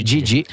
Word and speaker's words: Didi: Che noi chiamiamo Didi, ma in realtà la vera pronuncia è Gigi.